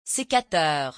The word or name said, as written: secateur